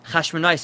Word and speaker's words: Chashmonai: So there's a big discussion how to pronounce the word. Is it Chashmonai or Chashmoni, I think, is Machlokas how to pronounce it. And it depends on whether Chashmonai is a first name or a last name. And some posts can bring this Gemara Zariah. Chashmonai seems like it's a first name, and therefore it should be pronounced Chashmonai as opposed to Chashmoni.